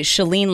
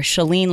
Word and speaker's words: Chalene: I would say Chalene like shut the door, or Chalene like Celine Dion, except I don't have her voice.